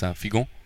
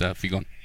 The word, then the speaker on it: Figon: Yeah, well, in Creole it's actually pronounced Figon. But yeah, in English, it's Figon.